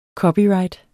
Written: COPYRIGHTENES

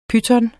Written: PYTONER

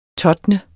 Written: TOTNET

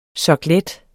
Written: soklet

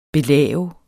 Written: belave

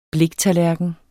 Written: bliktallerken

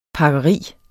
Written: pakkeri

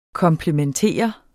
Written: komplementere

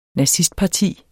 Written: nazistparti

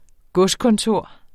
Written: godskontor

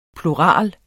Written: plural

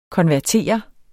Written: konvertere

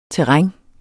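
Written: TERRÆNET

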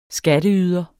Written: skatteyder

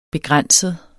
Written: begrænset

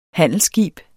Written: handelsskib

